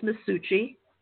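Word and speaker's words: Maschuci: Capital M-A-S yep. – C-H-U-C-I, and it's pronounced Masucci.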